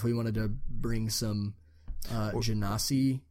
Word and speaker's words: genasi: Is that the proper pronunciation of that? Genasi? I think it is, because it's like genie, right? – Yeah.